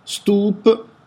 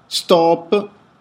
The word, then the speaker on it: Stoop: L'interrogativo si pone legittimo: Stoop, Stop. Sta di fatto che la band si scrive S di Savona, T di Torino, O di Otranto, di nuovo O di Otranto e la P di Palermo.